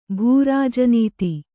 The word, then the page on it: ಭೂರಾಜನೀತಿ: bhū rāja nīti